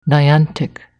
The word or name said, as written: Niantic